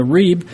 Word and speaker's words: rib: It's the rib, R-I-B, sometimes pronounced more like a V sound.